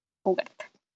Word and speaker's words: Uhart: G.B. con H, G.B. Ugart y se escribe igual U-H-A-R-T, Ugart.